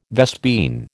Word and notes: vespine: American English

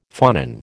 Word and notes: phonon: American English

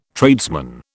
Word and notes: tradesman: British English

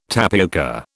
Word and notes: tapioca: British English

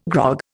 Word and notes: grog: Indian

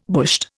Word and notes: bushed: Indian

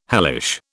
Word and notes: hellish: British English

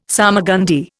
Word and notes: salmagundi: Indian

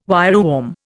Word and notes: wireworm: Indian

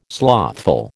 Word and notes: slothful: American English

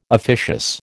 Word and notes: officious: American English

